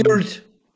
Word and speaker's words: Wyrd: Äh, W-Y-R-D. Sieht man oft das, das Wort, ich kann es noch nicht aussprechen. Ähm, Weird. – Weird. Weird. Okay, weird, dass man das nicht wie weird schreibt, aber weird.